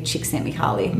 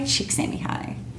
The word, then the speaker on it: Csikszentmihalyi: And it was the scientist who coined the term flow, Mihaly Csikszentmihalyi, mm-hmm. Which is not, that's not how you pronounce his name, because there's L's in there, but they're silent.